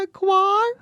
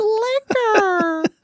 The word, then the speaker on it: liquor: And she's like, it's not liqueur, it's liquor.